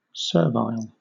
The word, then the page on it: servile: British English